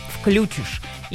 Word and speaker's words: включишь: И получается, что уже на сегодняшний день можно говорить и так, но если вдруг хотите выглядеть грамотным человеком, то, конечно, употребляйте слово с ударением на слог с буквой «и». Включишь. Именно так будет правильно.